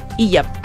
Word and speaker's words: hijab: por no usar su eh, eh, hija y hija Hijab. Ah, ¿cómo se pronuncia? El hijab, la. – La Bueno, la eso, eso, lo estaba pronunciando mal.